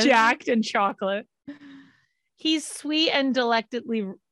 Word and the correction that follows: decadently: He's sweet and decadently.